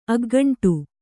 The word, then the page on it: ಅಗ್ಗಂಟು: aggaṇṭu